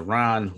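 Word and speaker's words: Rahn: Okay, well, I just found out there's a Ron Hortman Pandora station, so I'm kind of excited about that.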